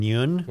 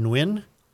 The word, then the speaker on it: Nguyen: N-G-U-Y-E. E-N, Nguyen. – What? Spell it again. – N-G-U-Y-E-N. – Quinn. – Quinn. – Quinn. Quinn. Yeah, Brian Nguyen? – Well, yeah, like Nguyen.